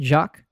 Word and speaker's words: Jacques: Did I say that right with the French pronunciation? Jacques? – Jacques.